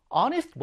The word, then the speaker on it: honest: 그 다음에 honest boy. honest가 정직한이죠. 좀 전에 봤던 우리 our처럼 h가 발음이 되지 않아요, 여러분들. 그러니까 honest, 정직한.